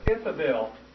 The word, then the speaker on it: Ithobal: His name was Ithabel. Ithabel the Second. That's spelled I-T-H-O-B-A-L.